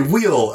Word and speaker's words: wheel: I think you're saying will as in will and testament. Do you mean wheel? – Will. Will. – Will. – Yes. I keep on not saying the H. – Spell the word. – W-H-E-L.